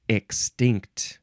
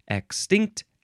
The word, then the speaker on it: extinct: Next is extinct. First form. You can emphasize the first or the second syllable. Extinct, extinct.